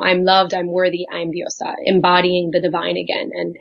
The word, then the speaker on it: diosa: But top of my list was to ask you what diosa is, and am I even pronouncing it right? – Yeah, diosa.